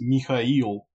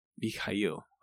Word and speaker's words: Mikhail: No, I mean, if you really want to be like correct, it's Mikhail, but you can just call me Michael.